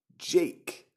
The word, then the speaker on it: JVKE: I am your host, and today we're talking about the song Golden Hour by JVKE. And yes, that's pronounced the same as my name, and no, I did not write or perform this song.